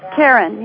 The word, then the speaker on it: Karin: Karen, yes. – Okay. – K-A-R-I-N.